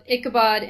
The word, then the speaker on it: Ichabod: The Adventures of Ichabod and Mr. Toad.